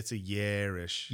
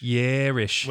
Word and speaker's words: Yarish: Is it Yish or Is? – And it's a long A. It's a Yarish. – Yarish.